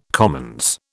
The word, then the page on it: commons: British English